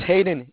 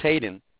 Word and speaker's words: Hayden: It's Hayden. It's Hayden. – How do you spell that? – H-A-Y-D-E-N.